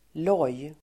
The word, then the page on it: loj: [låj:]